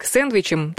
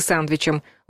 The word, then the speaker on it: сэндвичем: Двойной бутерброд может быть как сэндвичем, так и сандвичем. Варианты конкурируют. Первый, как пишет Грамота.ру, отвечает написанию, а второй — произношению слова на языке источники.